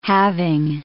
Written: having

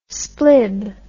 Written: splib

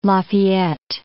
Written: Lafayette

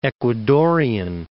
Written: Ecuadorian

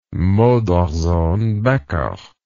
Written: modersohn becker

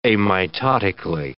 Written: amitotically

